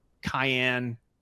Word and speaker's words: Cian: And they recently partnered with a protocol called CN or Cayenne. Clay, how the hell do I say that? C-I-A-N is how you say it.